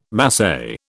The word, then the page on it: Masai: British English